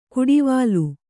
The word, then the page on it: ಕುಡಿವಾಲು: kuḍivālu